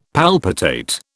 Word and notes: palpitate: British English

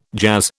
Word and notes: jazz: British English

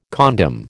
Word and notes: condom: American English

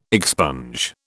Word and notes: expunge: British English

